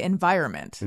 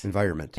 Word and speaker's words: environment: I think I say environment. – Environment. – Environment. But I don't think that's right.